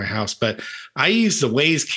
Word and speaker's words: Wyze: Wyze. – Wyze.